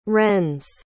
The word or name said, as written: wrens